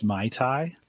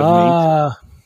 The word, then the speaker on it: MATE: Andrew wants us to know it's pronounced Mai Tai instead of mate.